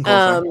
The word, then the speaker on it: Kolesar: Is that how we're pronouncing his name? – Yeah, Keegan Kolesar.